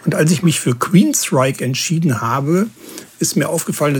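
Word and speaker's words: Queensrÿche: Also Queenstrike. Und übrigens, seinen Vornamen schreibt man ja G-E-O-F-F, aber man spricht nicht Joff, wie viele Leute das tun, sondern Jeff.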